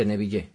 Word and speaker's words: Neville: Ah, la de Edgar Neville, sí. – Es este, Neville. Es que él decía que era Neville porque es español. – ¿Neville? – Claro. – O sea, lo pronunciaba con la L de Sevilla.